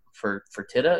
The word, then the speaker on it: Fertitta: Fertitta.